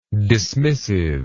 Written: dismissiveness

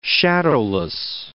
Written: shadowless

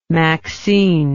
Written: maxine

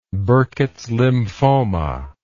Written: burkitts lymphoma

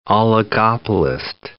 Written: oligopolist

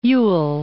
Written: Ewell

